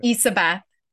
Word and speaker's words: Ysabeth: Ysabeth. It's so my name is spelled Ysabeth, but you can imagine the Y is like an E. That seems to help people put it together mentally.